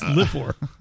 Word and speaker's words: livor: Is it pronounced liver or livor? Because it's spelled livor. – And livor, livor. – It doesn't matter. – Yeah. You're not a local. It's livor. Livor.